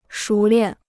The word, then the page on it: 熟练: shú liàn